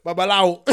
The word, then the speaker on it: Babalawo: Like Babalawo. That's how they pronounce it in Nigeria.